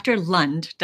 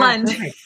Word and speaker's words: Lund: I am so excited today to have Dr. Lund. Did I say it right, Dr. Lund, right? – You did, yes. – Yeah, Lund.